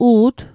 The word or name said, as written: u't